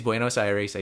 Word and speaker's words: Buenos Aires: it is Buenos Aires.